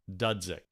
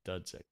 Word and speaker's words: Dudzik: Dudzik. – Yes. – Dudzik, got it.